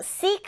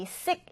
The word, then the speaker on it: seek: 자, seek 하면, 자, 일단은 이 단어는 우리 sick 이란 단어랑은 구분해서 알아두세요. 우리 sick 하면 아픈 이란 뜻이잖아요. 이때 짧은 아이 발음입니다. sick. sick인데, 이건 긴 아이 발음이에요.